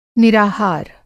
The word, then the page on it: निराहार: Hindi